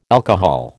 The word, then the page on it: alcohol: American English